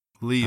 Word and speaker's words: Liyue: Liyue.